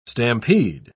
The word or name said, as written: stampede